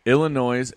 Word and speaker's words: Illinois: Indiana and Minnesota, Iowa and Purdue, Illinois and Minnesota, Illinois and Indiana.